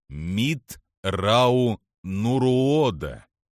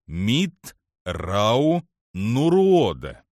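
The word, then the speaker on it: Митт'рау'нуруодо: «Мит Рау Нуруода», — поправил его Траун. – «Я так и сказал. Мит Рау Нуруода». – «Произносится Мит Рау Нуруода». – «Да, Мит Рау Нуруода».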